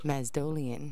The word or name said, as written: mazdolian